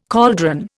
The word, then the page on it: cauldron: Indian